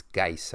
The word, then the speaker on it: Guice: It's Guice, I think. It's G-U-I-C-E. It's like Guice. I think that's how you say it.